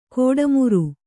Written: ಗಡಿಮೀರು

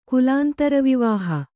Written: ಕುಲಾಂತರವಿವಾಹ

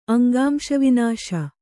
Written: ಅಂಗಾಂಶವಿನಾಶ

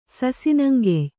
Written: ಸಸಿನಂಗೆಯ್

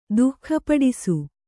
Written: ದುಃಖಪಡಿಸು

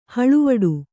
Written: ಹಳುವಡು

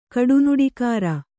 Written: ಕಡುನುಡಿಕಾರ

